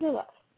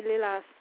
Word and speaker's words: Lilas: Lilith. Now, I'm probably not pronouncing it right, so. – Well, Lilith, you speak French. – I would say Lilas.